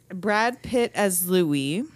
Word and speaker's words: Louis: Because I didn't think of it as the French pronunciation of Louis.